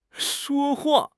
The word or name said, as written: shuo1 hua4